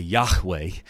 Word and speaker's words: Yahweh: And the best transliteration we have for that is Yahweh, or if you want to put the ha in there, the Hebrew pronunciation, it would be Yahweh.